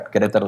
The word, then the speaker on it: Querétaro: And now you're in Querétaro, right? – Am I saying that right? Yep. No, that's right. Querétaro is a hard word to pronounce even for us Spanish speakers.